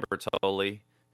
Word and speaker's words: Bertoli: Hi, I'm Ben Bertoli. Just like the pasta sauce, only with one less L. Not that that affects pronunciation.